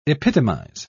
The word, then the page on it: epitomise: British English